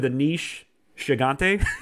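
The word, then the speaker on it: Aneesh Chaganty: I'm going to go with Anish Chigante.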